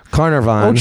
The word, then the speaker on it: Carnarvon: Yeah, no, he's right. It's Carnarvon.